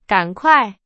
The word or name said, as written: 赶快